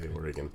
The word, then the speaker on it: Oregon: And I'm just looking at him going, Dad, that's not even close to how to say Oregon. – It's pronounced like the things inside your gut. – Like, yes. Organs.